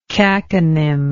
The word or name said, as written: caconym